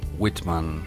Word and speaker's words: Whitman: A ver, dije Walt Whitman. ¿Está bien dicho? – No sé cómo se escribe, puede ser Whitman, ah, bueno, o Whiteman. – Lo voy a deletrear. W-H-I-T-M-A-N. – Whitman. – Whitman. Ay, me encanta esa pronunciación. Bien. – Entonces, White sería W-H-I-T-E.